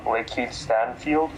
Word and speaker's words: Lakeith Stanfield: Lakeith Stanfield, is that how you say it? – Yeah, you got it.